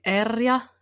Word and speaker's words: Erja: Well, the Finns would say Erja Lyytinen, but to help you guys out, you can say Erja Lyytinen.